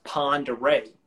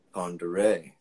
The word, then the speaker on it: Pend Oreille: It's spelled Pin Ray, no, or Pin Rai, but it's per, it's pronounced Pend Oreille. – Pend Oreille.